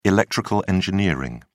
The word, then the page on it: electrical engineering: British English